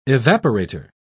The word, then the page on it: evaporator: British English